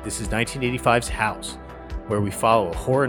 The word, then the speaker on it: House: One of the most bonkers films I've ever seen and an icon of the horror film genre, often mispronounced as Hausu, we're covering the Japanese film House, released in 1977.